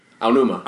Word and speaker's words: Aonuma: Sorry, Aonuma.